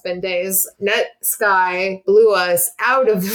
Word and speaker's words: Netsky: His name is pronounced Netsky, not Netsky.